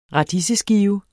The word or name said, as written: radiseskive